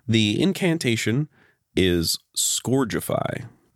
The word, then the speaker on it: Scourgify: S-K-U-R, all caps, is in the pronunciation. – Scourgify. That's the way he says it in the video game, in Harry Potter and the Chamber of Secrets on the PlayStation 2.